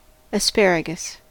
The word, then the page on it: asparagus: American English